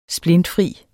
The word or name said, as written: splintfri